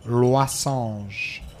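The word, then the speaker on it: losange: and it's loisange. Loisange. For you, Carlos, it's spelled the way it sounds.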